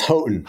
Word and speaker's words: Houghton: am I saying this right? – It's Houghton. Houghton. Yeah, that's a tricky one.